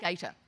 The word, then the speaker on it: Gaita: Raymond Gaita? Gaita? – Gaita, Ray Gaita.